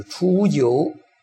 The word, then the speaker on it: 初九: 在这里怎么读法呢？就是初九，在第一爻中叫初，念初，初九，初九啊，它就变了，变什么呢？变成阴爻了，那就是说，变成八了。刚才讲过吗？第一行讲过了吗？爻变嘛，九变成就是。九变成八了嘛，初九